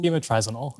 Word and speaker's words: Bimotrizinol: Is it Bimotrizol? – Bimotrizinol. – Bimotrizinol. Yes, exactly.